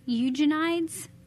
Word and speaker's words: Eugenides: Um, um, you, I don't know how to pronounce his last name. Jeffrey Eugenides, I think. That's how you say it.